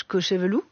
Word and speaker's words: Cochevelou: De la diaspora, votre père, Georges Cochevelou, c'est comme ça qu'on prononce. – C'est ça.